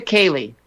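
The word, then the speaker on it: ceilidh: This is a Kaylee, pronounced K-A-Y-L-E-E, in Scotland or Ireland, a Kaylee.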